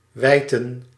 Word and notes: wijten: /ʋɛj.tə:/

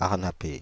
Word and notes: arnapée: /aʁ.na.pe/; France (Île-de-France)

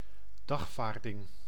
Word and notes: citation: France; /si.ta.sjɔ̃/

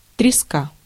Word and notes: morue: /mɔ.ʁy/; France

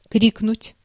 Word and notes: крикнуть: /ˈkrʲiknʊtʲ/

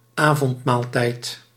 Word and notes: supper: /ˈsʌpɚ/; /ˈsʌpə/; /ˈsʌpə(r)/

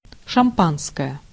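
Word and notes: шампанское: IPA: /ʂɐˈmpanskəjə/; šampanskoje; Russian

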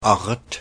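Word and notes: laji: [ˈlɑji]; Finnish

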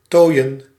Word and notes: adorn: American English; IPA: /əˈdoɹn/

